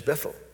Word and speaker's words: Bethel: Our name Bethel in Spanish is Bethel. H is silent in Spanish.